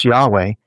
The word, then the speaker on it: Yahweh: When you put that all together, you have one common element, and that is a sacred name that is pronounced by the scholars as pronounced by the ancients with the Y sound as Yahweh. It's more like Java. When you see it in the text, it'll be J-A-B-E, or different spellings like this.